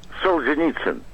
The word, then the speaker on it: Solzhenitsyn: Solzhenitsyn.